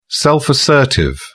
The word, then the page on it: self-assertive: British English